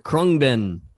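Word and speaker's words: Khruangbin: Is it Krungbin? Krungbin? – Krungbin. – Krungbin. – Love Krungbin.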